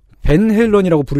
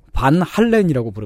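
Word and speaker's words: Van Halen: 벤 헬런이라고 부르지 않고 반 할렌이라고 부릅니다. 방송했어요 아, 특히 그 발음을 열심히 밀어주시는 분이 계세요. 음. 어, 논리가 이렇습니다. 네덜란드 음. 사람이니까 네덜란드어로 불러야 된다는 거예요.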